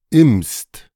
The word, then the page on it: Imst: ɪmst; German